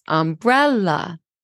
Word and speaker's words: umbrella: Now, when we say umbrella, the second syllable rises, umbrella, okay?